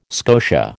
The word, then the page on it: scotia: American English